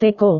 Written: teko

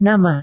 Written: nama

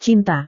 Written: cinta

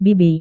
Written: bibi

